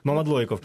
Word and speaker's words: Мамадлойков: Гурт «Тарута» – це Євген Єжак, Олена Романенко, Ростислав Мамадліков і також Ярина Товкайло. Одне прізвище. Чи правильно, чи неправильно? – Мамадлойков. – Мамадлойков. Перепрошую.